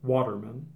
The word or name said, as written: Waterman